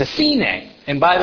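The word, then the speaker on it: sine (סנה): The Hebrew word for thorn bush in Exodus 3 is sine. Now, if you know Hebrew, sine sounds exactly like sine. What's the other sine? We pronounce it in English as Sinai.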